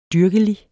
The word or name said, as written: dyrkelig